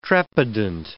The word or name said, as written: trepidant